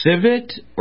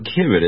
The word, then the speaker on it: civet: Well, it's either civet or civet. It's C-I-V-E-T.